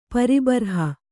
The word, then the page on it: ಪ್ರಬರ್ಹ: prabarha